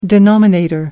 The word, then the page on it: denominator: American English